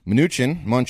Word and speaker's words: Mnuchin: Mnuchin, whatever you want to say. – No, you said it correctly the first time.